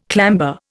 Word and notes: clamber: Indian

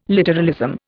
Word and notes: literalism: Indian

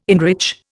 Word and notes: enrich: Indian

